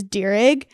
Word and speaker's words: Deerig: Like for me, my last name is Deerig, and I tell people it's like a deer and a pig, but you drop the P and add the ig onto the deer.